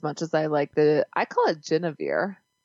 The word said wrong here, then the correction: genever: Like Guinevere, except Genevieve. – That's probably smart. – What, how did you say it? – Genever. Genever. It's like G-I-N-E-V-E-R.